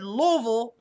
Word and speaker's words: Louisville: I promise by the end of the weekend, I will have you pronouncing Louisville correctly. Can't wait. – Louisville. – I say it right? Louisville. Louisville. I say Louisville. – The least least number of syllables you can pronounce is the correct way.